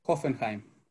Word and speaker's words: Hoffenheim: 4-1 contra el Oppenheimer. Pues, tú eres alemán, por favor, eh, ilumínanos. ¿Cómo se pronuncia? Hoffenheim. Muy bien, gracias.